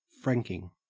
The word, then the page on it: franking: Australian English